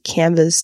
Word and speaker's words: canvass: Canvas voters 向选举人拉票，或者是 Canvas the neighborhood 向社区拉票。